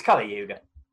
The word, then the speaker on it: Kali Yuga: It's Kali Yuga. – Until now, you've nailed the pronunciation.